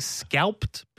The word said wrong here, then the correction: scalloped: potato? Scalloped. – You scalp them, buddy. – Scalloped. – Scalloped. – Scalloped. Extra syllable in there.